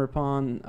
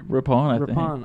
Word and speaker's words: Rippon: How do you say his name? – Rippon. – I always say that wrong. I always feel like we have him on the line. Adam. – Adam. it's Rippon.